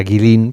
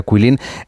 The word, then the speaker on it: Guilin: Quilín, como si fuera Guilin, pero se pronuncia Quilín.